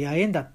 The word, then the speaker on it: 八重田: 猿田って書いて八重田って読むんですけども、その道を行く道もありますし、栗壺、まあ栗壺の方がいい、分かりやすいかな。